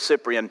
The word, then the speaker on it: Cyprian: Hopefully, it'll be the last time for a little while you hear about the plague of Cyprian. But there's something about that fits in so well here. Remember that plague of Cyprian? It's C-Y-P-R-I-A-N, soft C, C-Y if you want to Google it.